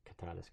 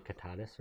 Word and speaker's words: Kittitas: K-I-T-T-I-T-A-S. On the continent of the Americas. – Sounds like Kittatas, right? – Kittatas, yeah. – Kittatas? – Kittatas.